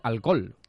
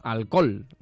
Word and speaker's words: alcohol: luego realmente dice alcohol. Es que son dos vocales seguidas con una muda entre medias. – Una muda entre medias que no se pronuncia. – Que no se pronuncia y realmente lo que se hace es una O larga.